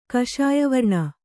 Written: ಕಷಾಯವರ್ಣ

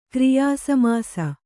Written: ಕ್ರಿಯಾಸಮಾಸ

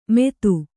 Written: ಮೇದು

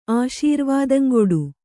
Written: ಆಶೀರ್ವಾದಂಗೊಡು

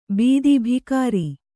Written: ಬಿತ್ತಿ

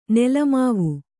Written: ನೆಲಮಾವು

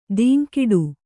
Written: ಧಿಂಕಿಡು

